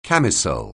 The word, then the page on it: camisole: anglais